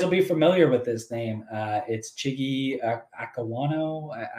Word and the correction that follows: Okonkwo: Akawanko. probably saying it wrong. Ak- Ak- Okonkwo. Okonkwo? Is that what it is?